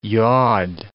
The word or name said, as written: yod